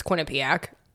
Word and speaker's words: Quinnipiac: it's Quinnipiac. You should you should pronounce that. I'm a thousand percent sure it's Quinnipiac.